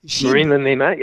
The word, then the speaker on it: Marina Nemat: You've got a Canadian, Marina Nemat. I don't know if I'm saying that correctly. – Marina Nemat, yeah.